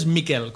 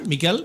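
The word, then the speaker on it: Michael: La pronunciación austríaca del nombre es Miquel, como si un Miguel catalán, Miquel.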